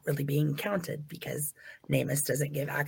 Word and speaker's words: NamUs: So this is from it's pronounced Namus. And it's okay. NAMUS, and it's where, you know, I talked about a little bit earlier.